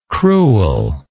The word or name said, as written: crewel